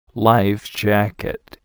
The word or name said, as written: life jacket